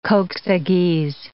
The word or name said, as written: kok-saghyz